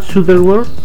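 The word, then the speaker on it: Shuttleworth: Matt Sutherworth, ¿sí se dice así? Creo que... – Sí. – Mark Sutherworth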